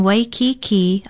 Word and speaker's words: Waikiki: Waikiki is spelled W-A-I-K-I-K-I with kaha ko over the last two vowels. Pronounce it Waikiki.